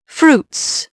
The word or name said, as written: fruits